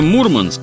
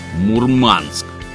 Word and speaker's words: Мурманск: По этой причине бытует мнение, что многие на севере произносят это название с ударением на второй слог «Мурманск». Но за время поездки от местных ни разу такого не слышал.